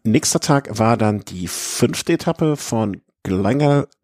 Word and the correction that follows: Glenelg: Glenelg, wie spricht man das? Glenelg? Glenelg? Glenelg, ja.